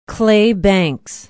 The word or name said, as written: Clay Banks